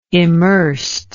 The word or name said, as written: immersed